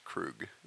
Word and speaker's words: Krug: It's Krug. – Krug. – Like Freddy Krueger. – Krug, without the er.